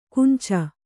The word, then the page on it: ಕುಂಚ: kunca